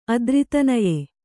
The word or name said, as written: ಅದ್ರಿತನಯೆ